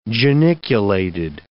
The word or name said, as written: geniculated